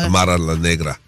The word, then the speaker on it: Amara La Negra: Amara La Negra.